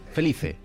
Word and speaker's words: Felice: Y es Simón Feliz o Simone Felice, no sé cómo se pronuncia, si es italiano o inglés. Simone, Simon Feliz. – Felice.